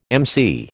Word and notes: MC: American English

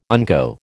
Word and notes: unco: American English

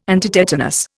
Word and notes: antitetanus: Indian

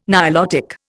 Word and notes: Nilotic: Indian

